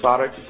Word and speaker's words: sarx: So Jesus incarnated in John chapter 1, verse 14, and the Greek word there, if I'm not mistaken, is sarx, something to that nature, S-A-R-X, is it pronounced? – Sarx.